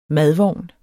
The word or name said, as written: madvogn